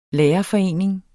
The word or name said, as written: lærerforening